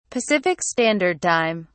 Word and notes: pacific standard time: British English

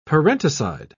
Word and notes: parenticide: British English

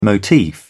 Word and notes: motif: British English

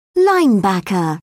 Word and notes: linebacker: British English

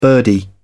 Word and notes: birdie: British English

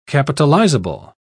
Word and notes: capitalizable: British English

